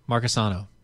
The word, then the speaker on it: Marcusano: I want to say the name right. Marcusano. – There you go. Hashtag nailed it. Hashtag like a native.